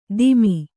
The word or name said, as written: ತಿಮಿ